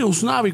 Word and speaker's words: Usnavi: Usnavi. Usnavi. Sí, Usnavi.